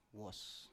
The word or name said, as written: wos